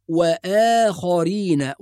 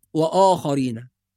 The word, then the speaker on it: وآخرين: ننتبه إلى نطق كلمة وآخرين. بعض الناس يقرأ وآخرين آخرين، وهذا خطأ، فالهمزة مرققة والواو مرققة، فالصواب وآخرين وليست وآخرين.